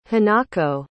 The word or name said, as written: hinako